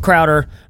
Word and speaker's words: Crowder: And unfortunately, we don't have the counterpart, the thing that balances Barrett out, Steph Crowder, rhymes with chowder, on the show today.